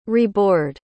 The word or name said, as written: reboard